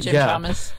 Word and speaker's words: Jim Chalmers: which Jim Chalmers or something? Is that how you pronounce it? – Jim Chalmers.